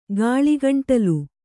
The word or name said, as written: ಗಾಳಿಗಂಟಲು